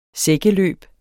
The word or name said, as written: sækkeløb